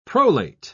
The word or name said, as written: prolate